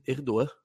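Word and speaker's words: Erdoğan: Seria o G do Erdogan, né? É mudo. Então, segundo o Daniel, uh, o pronuncia-se Erdogan. Er, Erdogan, seria assim, né?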